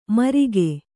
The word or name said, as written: ಮೂರ್ಕ